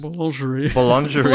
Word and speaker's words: boulangerie: Bongeries. – Boulangerie. Like I'm saying that wrong, you know.